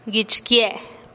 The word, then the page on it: guich kie': TdVZ